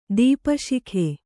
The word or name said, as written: ದೀಪಶಿಖೆ